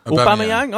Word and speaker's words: Aubameyang: Can I just pick you up on your pronunciation of Aubameyang? Is that Aubameyang. Official? Is that officially how it goes? – Aubameyang? – I go Aubameyang, yeah. But no, I like your one.